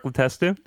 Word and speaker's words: Letestu: I'm going to butcher this name, but Letestu? – Mark Letestu? – Yeah, thank you very much.